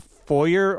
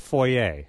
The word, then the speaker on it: foyer: The best pronunciation supposedly is foyer and not foyer, which sounds more affected, supposedly.